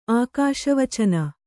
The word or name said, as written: ಆಕಾಶವಚನ